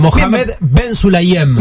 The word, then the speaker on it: Mohamed Ben Sulayem: Mohamed Ben Sulayem, su nombre. – Ay, qué árabe. – Bien lo pronunciaste, ¿eh? – Sí, me encantó. Muy bien, vamos de vuelta. Mohamed Ben Sulayem.